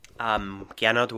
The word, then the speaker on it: Gernot: This is Gernot Wagner, a tricky name to pronounce for Americans. – Gernot Wagner, juggernaut without the jug.